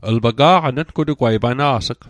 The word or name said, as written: pana's'g